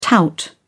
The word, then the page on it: tout: British English